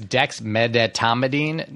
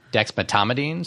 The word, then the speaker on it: dexmedetomidine: One is I see this mispronounced all the time. It's dexmedetomidine, not dexmedetomidine. So people leave out that middle syllable.